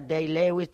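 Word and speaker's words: Day-Lewis: D. Lewis, es que se pronuncia Day Lewis, D. Lewis, no sé, el autor. No, Daniel actor. Day Lewis.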